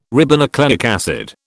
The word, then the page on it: ribonucleic acid: British English